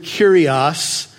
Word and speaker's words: kurios: When Jesus uses the word master, that's the Greek word kurios. It's spelled K-U-R-I-O-S if you're a note taker.